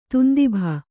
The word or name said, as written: ದುಂದುಭಿ